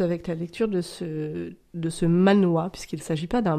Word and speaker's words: manhwa: D'accord, on dit manhwa. – Alors, je ne sais pas si c'est comme ça que ça se dit, mais en tout cas, M-A-N-W-H-A, d'accord. – Je prononce manhwa.